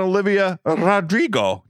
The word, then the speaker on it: Olivia Rodrigo: Olivia Rodrigo. Did I say her name right? – You did.